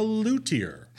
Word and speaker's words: luthier: Because today, our guest is a luthier. Yes, a silent H.